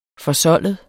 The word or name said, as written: forsoldet